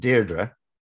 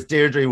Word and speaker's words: Deirdre: Well, there's lots of different pronunciations. She would pronounce it Deirdre. – Deirdre. Okay, well, I'll pronounce it Deirdre. – Whereas Deirdre would be the Coronation Street pronunciation.